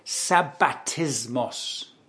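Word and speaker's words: sabbatismos (σαββατισμός): That word rest in Greek is sabbatismos. Sabbatismos. What does that sound like to you? Sabbatismos.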